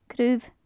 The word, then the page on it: craobh: gaelic